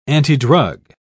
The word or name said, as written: antidrug